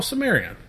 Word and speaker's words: Cimmerian: Sumerian? Oh, Sumerian. Yeah, but Sumerian with a C.